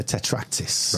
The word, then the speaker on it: tetractys: The tetractys. Yes. – How do you say it? – The tetractys.